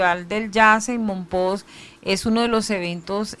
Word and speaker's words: Mompox: Bueno, pertinente pregunta, porque el Festival de Jazz, Festival Internacional de Jazz de Mompox, se escribe con S o con X, pero todos decimos Mompox con J, convoca a artistas de mucha importancia a nivel de este género.